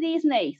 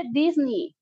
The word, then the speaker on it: Disney: Ya saben que igual hubo este video de que no se dice Disney, se dice Disney y así.